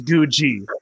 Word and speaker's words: Googie: It's like Google with an I instead of an L. So, okay. Yeah. Guji.